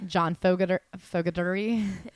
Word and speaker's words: Fogerty: Am I saying that right? – I think it's Fogerty. – John Fogerty. – Yeah.